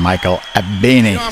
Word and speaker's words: Michael Abene: Maceo Parker, samen met het WDR Orkest, het WDR Big Band onder leiding van Michael Abene. Zo moet je dat uitspreken.